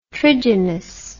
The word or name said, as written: trigynous